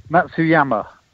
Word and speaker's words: Matsuyama: Matsuyama.